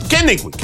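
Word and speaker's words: Kennewick: ¿Kennewich o Kennewich? ¿Cómo? – Kennewich.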